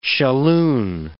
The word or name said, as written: shalloon